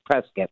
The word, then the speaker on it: Prescott: So Prescott is spelled P-R-E-S-C-O-T-T. Now, correct. – Is it Prescott or is it Prescott? – Depending on where you're from. I guess if it's California, it's Prescott. If you're from Arizona, it's Prescott. – Yes, Prescott. It's like biscuit, but you don't, because I always feel like, am I pronouncing it right?